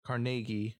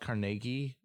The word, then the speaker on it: Carnegie: So Andrew Carnegie or Carnegie, it's apparently pronounced Carnegie, but whatever. – Okay. And I'm familiar with it as Carnegie, but that could just be Americanized.